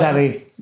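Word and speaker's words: Ndunderi: Now, one of the specialities I read, which this might be completely wrong, so you can correct me, is it called Nudinari? Is that right? Am I butchering the pronunciation? It's a type of gnocchi made with flour. – Dundari. – There we go. Completely butchered. – Dundari. No, it's all right. Dundari. Dundari. – With a silent N at the front.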